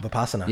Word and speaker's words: Vipassana: Is it Vipassana? – Vipassana.